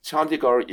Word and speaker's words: Chandigarh: So he has a bachelor's degree in chemistry from Punjab University in Chandigarh, India.